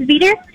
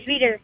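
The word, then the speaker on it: Veater: V is in Victor, E-A-T-E-R. – Oh, just how it sounds. – Just like an egg beater, but a Veter. – Just like eater with a V.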